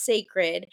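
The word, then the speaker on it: Cécred: And the brand is pronounced Sacred, but it's like in a beautiful, like, I don't know if it's French or whatever, but it's pronounced Sacred. And I'm like, it's about dang time.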